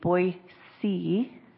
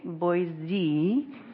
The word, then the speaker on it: Boise: So if you're an Idaho native, the way you actually pronounce Boise is Boise. And we know you're not from Idaho if you say Boise.